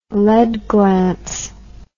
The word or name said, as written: lead glance